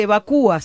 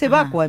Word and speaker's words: evacua: Por ejemplo, yo siempre, viste que se dice evacúa siempre, y es evacua en verdad. Lo mismo que no es licúa, es licua. – Claro. – Y la mayoría de las veces se escucha con el acento en la U.